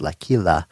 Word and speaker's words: L'Aquila: So, you know, the researchers, Valentina Sochi and Michelle Ferrara from University of, I believe it's pronounced L'Aquila in Italy, had this to say, I'm quoting, "The potential of cocoa flavanols to protect cognition in vulnerable populations over time by improving cognitive performance.